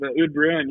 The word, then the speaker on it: Oud Bruin: I was looking at, how do you pronounce it? The Ode Bruin or Oud Bruin line? – Yeah, the Ode Bruin.